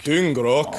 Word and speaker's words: dyngrak: Dyngrak. Dyngrak. Dyngrak. rock. How's my pronunciation? Uh, pretty good, actually.